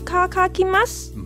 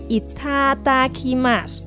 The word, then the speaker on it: いただきます: 卡卡，いかかきます。 – 不是卡卡啊，是哒哒嘛。 – 不是卡卡咩？我不信，我要听多次正确的读音。 – いただきます。 – 吃饭啦，真系唔系卡卡、哦，不过都唔系哒哒咯，系 itadakimasu。 – 哦，原来系哒哒之真，系 itadakimasu。